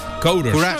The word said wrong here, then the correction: courage: Courage. – ¿Cómo? – Courage.